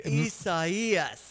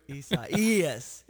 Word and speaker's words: Isaias: Isaias. They have the two capital E's on the pronunciation, okay, right in the middle.